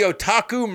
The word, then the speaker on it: otaku: But yeah, they dubbed him the Otaku Murderer. Said it right that time. – Yeah, you did.